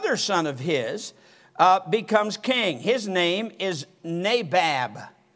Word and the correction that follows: Nadab: Nadab, I'll say it right.